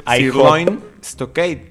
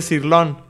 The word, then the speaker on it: Sirloin Stockade: Si no me equivoco es sirloin stockade. Sirloin. Pero mucha gente dice sirlón.